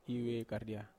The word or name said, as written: (ve)kardia